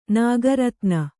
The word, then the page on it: ನಿಕರ್ತನ: Kannada